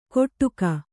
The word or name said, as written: ಕೊಟ್ಟುಗ